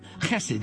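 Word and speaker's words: Chesed (חסד): Chesed is a guttural term.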